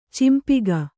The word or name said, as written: ಚಂಪಕ